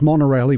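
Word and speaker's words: Monaurail: Osaka Monorail. I think you do say it as monorail, even though it's not spelt that way. M-O-N-A-U-R-A-I-L. I'm pretty sure it's pronounced that way.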